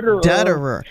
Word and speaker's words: Dutterer: Becky Dutterer. – It rhymes with Rudd.